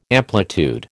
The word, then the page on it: amplitude: American English